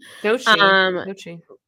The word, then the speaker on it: Doechii: Okay, I literally learned who Doechii was last night. I hope I'm even pronouncing that right. I think I am. – Doechii.